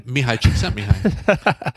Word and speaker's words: Csikszentmihalyi: Mihaly Csikszentmihalyi. It's a Hungarian name from Romania.